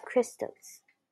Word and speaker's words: Christos: The X is said to represent the Greek letter G, the first letter in the Greek word for Christ, pronounced Christos.